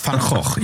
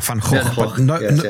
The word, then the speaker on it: Van Gogh: Van Gogh, and she said, it's Van Gogh. Yeah, Van Gogh. But nobody can say that other than the Dutch, really.